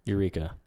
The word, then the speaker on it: Yreka: Eureka. – But it's spelt Y-R-E-K-A. – I guess that's how that is then. – I'm just going to say Eureka.